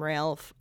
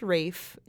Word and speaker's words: Ralph: And I would like to just apologize to him for all past episodes where I did call him Ralph. I know now that it is pronounced Rafe, and Jacob knew it all along.